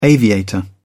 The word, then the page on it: aviator: British English